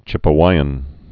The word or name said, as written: Chipewyan